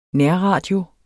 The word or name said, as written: nærradio